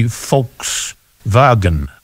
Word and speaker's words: Volkswagen: It could be Volkswagen. – Yeah, if you pronounce the W as the Germans do, as a V.